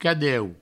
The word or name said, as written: cadeu